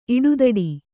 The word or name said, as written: ಇಡುತೊಡು